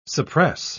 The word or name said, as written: suppress